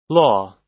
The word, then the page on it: law: American English